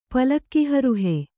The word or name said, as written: ಪಲ್ಲಕ್ಕಿಹರುಹೆ